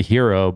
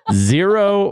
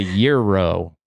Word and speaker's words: gyro: I always pronounced it the hero, but apparently it's either the zero or the euro.